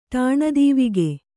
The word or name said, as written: ಠಾಣದೀವಿಗೆ